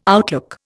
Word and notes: outlook: Indian

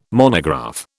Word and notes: monograph: British English

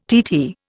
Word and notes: TT: Indian

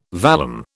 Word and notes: vallum: British English

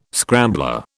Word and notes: scrambler: British English

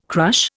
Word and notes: crush: Indian

